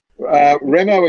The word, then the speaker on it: Remo: Remo is in demo, although my wife likes to recall someone having me said that to them, said, Remo as in demo?